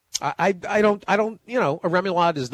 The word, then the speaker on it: remoulade: Is it remoulade? Am I pronouncing that correctly? – Remoulade is correct.